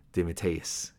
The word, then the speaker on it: DeMatteis: DeMatteis is how you say that name.